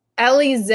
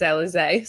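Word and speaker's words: Eliz: Is it Elize? Is it Elise? – Elize. I used to call her Miranda Elise for like literally months, and it's Elize.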